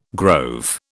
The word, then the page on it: grove: British English